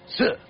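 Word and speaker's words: si (四): On account it's pronounced si, which sounds like the Chinese word for death, si.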